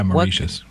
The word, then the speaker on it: Mauritius: Even today, I actually got an email from someone I sold a few items to in Mauritius. I think that's how you say the country's name. – Mauritius.